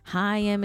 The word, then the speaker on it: Haim: I have wronged you all because I mispronounced Haim. Haim. Okay, the band that I formerly called Haim is actually pronounced Haim. Haim is the band's name, not Haim.